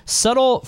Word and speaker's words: subtle: Subtle fraudulence. Hope I subtle. – Yeah, I felt like I said it without the B, even though they're you don't pronounce the B.